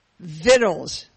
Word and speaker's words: victuals: It's called Vittles, is that right? – Yes, it's called Vittles because that's the correct pronunciation of the word. It's spelled V-I-C-T-U-A-L-S.